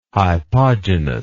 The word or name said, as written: hypogenous